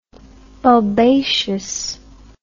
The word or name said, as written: bulbaceous